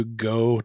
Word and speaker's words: Geaux: I did want to name my son Bro, but in the Cajun style, as in the LSU fans who do Go Tigers, and they they spell it G E A U X.